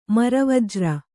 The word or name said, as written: ಮರವಜ್ರ